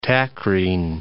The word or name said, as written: tacrine